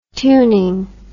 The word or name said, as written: tuning